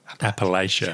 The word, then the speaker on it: Appalachia: Released a new patch, Wild Appalachica. – Appalachia. – Yeah, Appalachia. I keep saying it wrong.